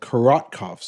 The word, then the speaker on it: Korotkoff: They are called Korotkoff sounds, not Kordakoff.